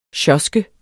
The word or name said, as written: sjoske